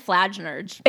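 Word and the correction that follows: Flajnik: Ben Flanick, but it's spelled with a J.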